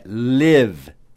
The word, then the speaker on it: live: L-I-V-E. Agora, presta atenção, porque se você for dizer o verbo viver, morar, escreve-se da mesma maneira, mas tem outra pronúncia. L-I-V-E. É live. Live. E a palavra, o adjetivo, né? E também o advérbio ao vivo é escrito da mesma maneira, mas pronuncia-se live. I, né? É o som longo do I.